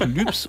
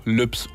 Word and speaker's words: Lübs: Aber auch Lübs und Klütz, also gesprochen mit langem Ü, die werden immer mal wieder zu Lübs und Klütz.